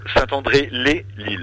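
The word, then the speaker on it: Saint-André-lez-Lille: Les Lilles ou les Lilles, comment est-ce qu'on prononce, dis-moi. – Saint-André les Lilles. – Les Lilles, très bien.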